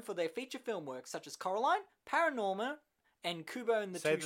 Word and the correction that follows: ParaNorman: Say it again. – Paranorman. – It's Paranorman, yes.